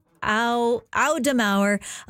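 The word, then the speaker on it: Auf der Maur: Back in April, Gloria Makarenko sat down with Melissa Audemars I'm pronouncing that completely wrong. That's Melissa Audemauer, a Montreal musician and former bassist for Hole and Smashing Pumpkins.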